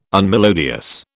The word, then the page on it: unmelodious: British English